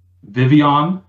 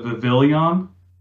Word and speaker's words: Vivillon: I just found out that Vivian is the way you pronounce it instead of Vivillon. – Mm-hmm. – That blew my mind because of the present. – The L's are silent.